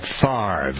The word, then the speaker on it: Favre: and we'll start with a focus on Brett Favre.